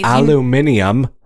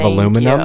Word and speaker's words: aluminium: Aluminium. – Oh, no. – It's of aluminum.